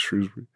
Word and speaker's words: Shrewsbury: Even people from Shrewsbury will tell you it's Shrewsbury.